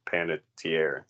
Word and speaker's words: Panettiere: We skipped right over Kirby from Scream 4, played by Hayden Panettiere.